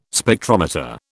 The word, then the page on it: Spectrometer: British English